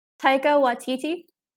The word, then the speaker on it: Taika Waititi: Taika Waititi, I believe it is.